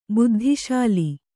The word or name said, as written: ಬುದ್ಧಿಶಾಲಿ